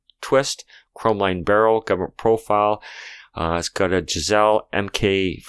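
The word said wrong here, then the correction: Geissele: I said Giselle? Isn't that kind of how you pronounce it? No, it's Geisley. – You're right. I don't know. – You're right. It is Geisley.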